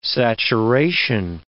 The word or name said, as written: saturation